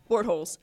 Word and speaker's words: Bortles: Bortles.